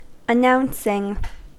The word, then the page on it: announcing: American English